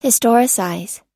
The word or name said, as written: historicize